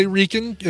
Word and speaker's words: Reichen: I would say Reichen. If it's German, you always pronounce the second of the two vowels.